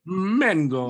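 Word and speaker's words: Mengo: And it's spelled, it's M-E-N-G-O, but it's pronounced pretty much the same. Mango.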